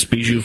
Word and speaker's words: Bijou: Well, his wife, her name is, I think it's pronounced Bijou. Is that sound right? – Yes, Bijou Phillips.